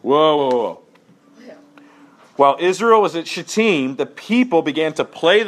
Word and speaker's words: Shittim: While Israel was staying in Shittim... – Thank you for pronouncing that correctly, by the way. Guy in my residency class didn't. It was a bit awkward. – Oh. – Shittim is perfect, yes.